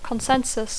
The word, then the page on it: consensus: English